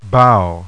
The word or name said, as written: BOW